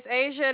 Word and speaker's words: Asia: My name is Asia. It is spelled A-S-I-A.